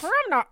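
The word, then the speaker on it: Perri Nemiroff: How did you say her name? – Perry Nemiroff. – The way you just said it was like Perry Nemiroff. Perry Nemiroff. – Perimnerov? – Yes. Okay.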